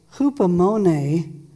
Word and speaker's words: hupomone: It is hupomone. That's how it's pronounced. Hupomone.